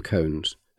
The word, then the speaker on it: koan: Some are poem cones, and different words appear as they revolve. As you will see on the Sculpture Trail map, the word cone is spelt K-O-A-N, not C-O-N-E.